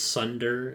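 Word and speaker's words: tsundere: It's like T-S-U-N-D-R-E. – Oh, yeah, it starts with a T. – It's Sunder.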